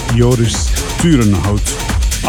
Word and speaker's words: Joris Turenhout: Take me away from Joris Turenhout, or I think I need to pronounce that in Dutch. It's Joris Turenhout on Respect Recordings.